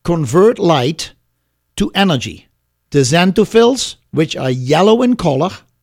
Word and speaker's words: xanthophylls: The xanthophylls, a tough word, xanthophylls is spelled X-A-N-T-H-O-P-H-Y-L-L-S. X-A-N-T-H-O-P-H-Y-L-L-S.